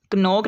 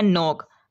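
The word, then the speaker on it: knock: कनोक नहीं है, ये है नोक।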